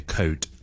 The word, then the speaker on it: code: 咁我哋有啲广东人或者有啲香港人咧，就会选择用一个最接近嘅声音嚟去读佢，咁就会读成曲啦。咁啊，正确发音系 code。